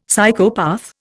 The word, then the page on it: Psychopath: Indian